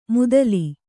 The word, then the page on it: ಮುದಲಿ: mudali